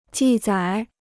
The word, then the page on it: 记载: jì zǎi